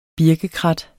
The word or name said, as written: birkekrat